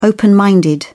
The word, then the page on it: open minded: British English